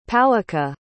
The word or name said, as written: powaqa